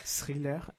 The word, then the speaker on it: thriller: Thriller. Attends, vous prononcez ça comment? – Ouais. Thriller. – Ouais, thriller. – Ouais, tu l'as bien prononcé.